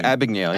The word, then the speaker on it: Abagnale: Abagnale, yeah.